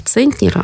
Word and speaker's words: центнер: Если это слово, например, «центнер», оно из немецкого языка, то там ударение сохраняется, как и в языке исходники, то есть на первый слог.